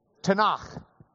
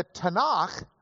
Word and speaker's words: Tanakh: But the Tanakh comes from three different letters in that word, T, N, and the single Hebrew letter, which we pronounce as a KH sound.